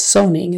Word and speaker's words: zoning: Zoning with a Z. Make sure your Z is voiced, not an S. Not zoning, it's zoning. Certainly is spelled like certainly with an A-I, but we say it like an I-N.